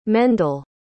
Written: Mendel

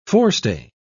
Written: forestay

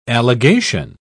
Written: allegation

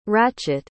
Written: rachit

